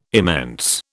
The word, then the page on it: immense: British English